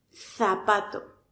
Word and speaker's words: zapato: mientras que en esos lugares se pronuncia como S por ejemplo, zapato, en el español estándar lo pronunciamos como zapato, zapato. Colocamos la lengua entre los dientes y expulsamos aire, como si nos fuéramos a morder la lengua.